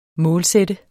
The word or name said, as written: målsætte